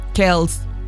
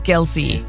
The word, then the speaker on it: Kelce: Lana del Rey podría cantar en la boda de Taylor Swift y Travis Kelce, que yo me enteré que se pronunciaba Kelce hasta hace como 3 días que la escuché en CBS. – ¿Cómo le decía? – Kels. Y es Kelce.